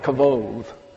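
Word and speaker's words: kavod (כבוד): you have K-A-V-O-D-T-H. That would be a transliteration into English and would be pronounced kavod.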